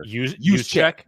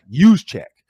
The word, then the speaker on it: Juszczyk: Is it Chizik or Juszczyk? – Juszczyk.